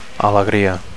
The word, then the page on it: alegria: Catalan